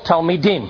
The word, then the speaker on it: talmidim: Plural, talmidim. – Talmid. – Okay. Notice it's I-M, not I-N. Talmidim. That's plural, more than one.